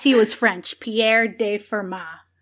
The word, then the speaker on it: Fermat: math class I've ever been in, it's been Fermat. Don't pronounce the T at the end.